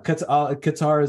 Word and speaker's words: Qatar: It's pronounced Qatar. Thanks, everyone. – Qatar. Qatar. – Cutter. – Cutter. – Cutter. – That's the worst one. Yeah, Qatar is acceptable. Cutter is a tough one.